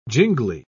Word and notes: jingly: British English

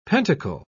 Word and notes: pentacle: British English

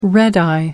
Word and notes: red-eye: American English

